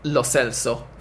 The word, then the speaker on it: Lo Celso: Apparently, when asked about it on the radio earlier, but they had another person on who said that UEFA's own pronunciation guide a couple of years ago suggested Lo Celso for it,